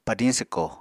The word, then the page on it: padiñ swko: Apatani